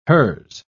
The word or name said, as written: hers